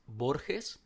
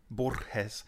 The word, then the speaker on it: Borges: I think it's Borges, the right pronunciation. Do you think you can do it? – Borges. So there's more of a rolling of the R.